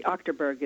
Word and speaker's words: Achterberg: Well, I answer to a lot of things, Gary. Achterberg is the preferred, I think.